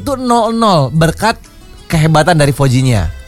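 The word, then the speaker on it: Vozinha: Bacanya Fojinha biasanya ya. – Fojinha.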